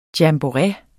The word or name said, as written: jamboree